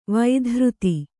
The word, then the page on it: ವೈಧೃತಿ: vaidhřti